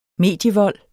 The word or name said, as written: medievold